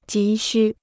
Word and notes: 急需: jí xū

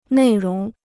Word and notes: 内容: nèi róng

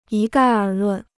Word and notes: 一概而论: yī gài ér lùn

